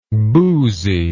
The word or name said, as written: booziness